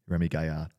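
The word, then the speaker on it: Rémi Gaillard: Have you ever seen a Remy Gaillard video? That's my French pronunciation. Remy Gaillard.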